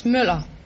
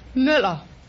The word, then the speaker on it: Müller: Nina Mahler, is that right? – No, it's Müller. – It's what? – Muller. – How do you spell it? – M U L L E R. – M U L L E R. What kind of a letter is U? – Yeah, it's it's it's it's U. It, it's an O uh, with a with a line through. Oh. It's pronounced U.